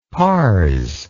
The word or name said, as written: pars